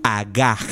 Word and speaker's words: hagah: esa palabra en el original que fue escrito, en el hebreo original que fue escrito, es la palabra agaj. Se escribe H-A-G-A-H. Agaj, agaj, agaj, agaj.